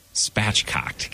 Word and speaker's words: spatchcocked: Rosemary, garlic, spachoked. Spachoked. Is that how you say it? – Spatchcocked. God.